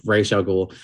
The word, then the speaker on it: Ra's al Ghul: Talia's dad, pronounce it the correct way. Don't listen to what the fuck Liam Neeson says about Ra's al Ghul. That's not his name. Ra's al Ghul.